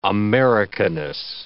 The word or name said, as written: Americanness